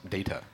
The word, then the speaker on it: Data: It is actually pronounced Data.